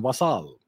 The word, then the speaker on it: vassal: Yes, vassal. I use the French pronunciation, vassal.